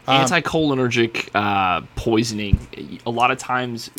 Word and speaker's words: anticholinergic: cholinergic delirients. – Anticholinergic is how you pronounce that one.